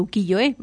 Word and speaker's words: ukiyo-e: Y todo ello, pues, inspirado en la técnica del ukiyo-e.